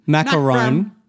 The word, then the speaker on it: macaron: how are they pronounced? Macaroon. Macaroon. Macarum. – Macaron.